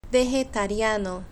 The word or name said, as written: vegetariano